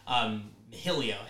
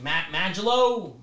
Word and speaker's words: Majillo: Mahillo, M-A-J-I-L-L-O. People couldn't pronounce it. So people were like, Magilo? Mahio? – Mahio.